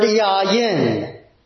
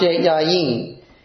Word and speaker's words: yayin: And the word for wine there is the Hebrew word yayin. Sometimes pronounced yayin.